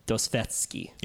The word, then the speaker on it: Dostoevsky: And it's a quote by a Russian novelist named Fyodor Dostoevsky. – Yes. – Am I pronouncing that right? – Yes.